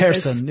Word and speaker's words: Persson: And Persson, I believe. – Persson.